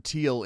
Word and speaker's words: Thiel: That is definitely how you pronounce Teal. Here's how you spell Teal. T-H-I-E-L. That's how you spell Teal. – Exactly how it sounds.